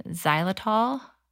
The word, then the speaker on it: xylitol: I needed a little something and made it with almond flour and xylitol.